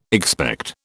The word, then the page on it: expect: British English